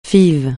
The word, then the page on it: FIV: francais